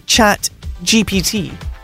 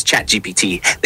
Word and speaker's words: ChatGPT: When French speakers pronounce ChatGPT, they say the letters as chat, sha, which is also the French word for cat, GJ, P, pe, T, te.